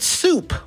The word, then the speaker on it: Suep: And then the band Soup, which is spelled S-U-E-P, in case you're trying to go look for that on the old internet and Bandcamp and buy it.